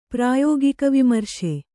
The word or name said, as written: ಪ್ರಾಯೋಗಿಕವಿಮರ್ಶೆ